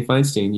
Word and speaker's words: Feinstein: Kenny Feinstein.